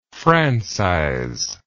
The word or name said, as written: francizer